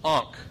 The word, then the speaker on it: ankh: This hieroglyphic letter is pronounced Ankh, and it means life.